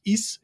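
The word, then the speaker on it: Ys: And I said, look, in this video, I also mentioned David Byrne and Joanna Newsom and Joanna Newsom's album, which I always mispronounce. I think it's pronounced Is, it's Y S.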